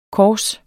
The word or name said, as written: kors